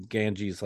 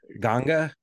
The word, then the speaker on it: Ganges: They're also pronouncing Ganges like the Ganga. – Ganga. – Which is sort of how most people in India, I guess, pronounce it. – Maybe the Hindi. – Us Americans are like Ganges.